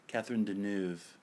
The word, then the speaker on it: Catherine Deneuve: Catherine Deneuve.